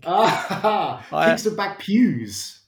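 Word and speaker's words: Kingston Bagpuize: Kingston Bagpews.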